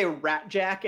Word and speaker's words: Rasczak: They all say Ratjack in the film, but it's actually spelled like a Czech spelling, RASCZAK.